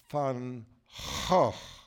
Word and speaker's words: Van Gogh: The original way to pronounce the Netherlands, the Dutch, is Van Gogh. That's the correct way. It's the guttural.